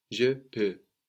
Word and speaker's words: peux: And we said that both of these are spelled EUX, no? VEUX or PEUX? And that X is silent, no? Je veux. – Je peux.